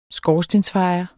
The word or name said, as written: skorstensfejer